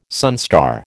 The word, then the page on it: sunstar: American English